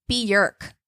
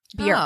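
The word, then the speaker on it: Björk: But what I learned while I was doing this is that, did you guys know that her name is not pronounced Bjork? – Mm-mm. – It's Bjork, like jerk. Bjork.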